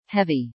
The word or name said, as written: heavy